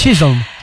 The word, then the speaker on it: Chisholm: Estuvo Emma Bunton y Mallory, aquí me cuesta un poquito este apellido, Chisholm, ¿lo dije bien? – Chisholm.